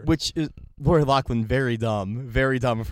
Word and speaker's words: Loughlin: Lori Loughlin.